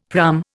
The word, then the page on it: praam: Indian